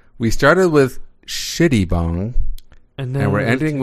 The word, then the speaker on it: Shittibong: B-O-N-G. – I'll spell it. S-H-I-T-T-I-B-O-N-G. Shitty bong.